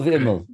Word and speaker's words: Imeall: I'm probably going to say this wrong, but I want to practice it because I want to be able to say it right. A Dov Imal. – Lovely. Dov Imal.